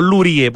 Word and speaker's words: Lurie: ¿Cómo se pronuncia? ¿Luri o Lurie? Porque no creo que la S pronuncio, ¿sí? – Luri. – Luri. Boris Luri.